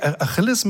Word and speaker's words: Achille: Well, I say Achilles, Achilles, is it? – Yes. Achilles. I don't know how you say it, but we say Achilles. Mm. In France, they call it Achille. over here, Achilles.